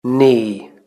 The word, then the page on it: knee: British English